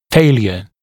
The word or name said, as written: failure